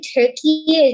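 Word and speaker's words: Türkiye: Actually, Turkiye is just saying Turkey, but in the language of Turkish. In Turkish, they say Turkiye.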